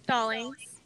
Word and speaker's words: Stallings: Stallings. – Stallings. Okay. – Yeah. So Marykate Stallings.